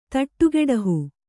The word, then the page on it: ಥಟ್ಟುಗೆಡಹು: thaṭṭugeḍahu